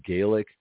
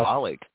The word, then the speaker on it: Gaelic: So Gaelic. Okay, while they are the same, right. – There are differences, right? So it actually originates from Ireland, which is Gaelic, but up there it is pronounced Gaelic.